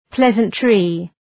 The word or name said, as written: pleasantry